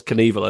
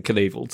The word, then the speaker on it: Knievel: It's weird that the K is pronounced in Knievel too. – Yeah. – You don't say knife.